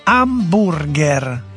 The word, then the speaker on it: hamburger: Sì, ma chi se ne frega, si pronuncia alla tedesca, hamburger.